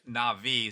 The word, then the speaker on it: Nabi: In the Old Testament, the prophets were called Nabi, N-A-B-I, Navi is probably closer to the pronunciation,